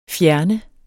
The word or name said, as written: fjerne